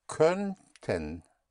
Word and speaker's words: könnten: In German, you put the umlaut over the O of konnten and you will get könnten. Könnten. Ö, like in sir, like in für. Könnten.